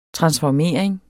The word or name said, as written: transformering